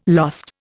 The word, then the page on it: lost: Indian